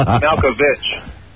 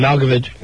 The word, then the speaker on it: Malkovich: Hey, it's Malkovich.